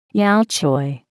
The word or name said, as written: yau choy